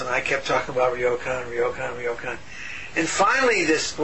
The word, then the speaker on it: Ryokan: And now, at the start of your book of poems, While We've Still Got Feet, you have an epigraph from Ryokan. Am I saying that right? – Yeah, that's fine.